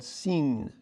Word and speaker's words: Sin: For instance, in Assyria, the god of the moon, which was called Sin, S-I-N, like the word sin, but there was a long I.